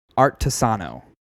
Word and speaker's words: Artesano: Artesano.